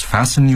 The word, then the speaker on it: fasten: Please fasten your seatbelts and observe the no smoking sign. – Fasten，中国学生常把它读成 fasten，所以这里的 t 是不发音的。